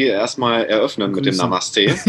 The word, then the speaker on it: Namaste: Namaste, mein lieber Alex.